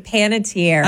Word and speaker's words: Panettiere: I still can't get over the fact that Hayden Panettiere, is that how you say it? – Panettiere.